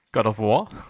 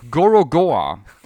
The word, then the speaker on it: Gorogoa: God of War? – Gorogoa.